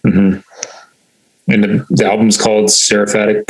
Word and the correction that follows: Seraphic: Punishment, right? Is that how you say it? – Seraphic Punishment. Yep.